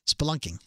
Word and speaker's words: spelunking: What's the word? – Spelunking. – Spelunking. Close enough.